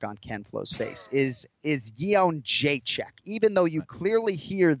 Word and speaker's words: Jędrzejczyk: Joanna Jędrzejczyk.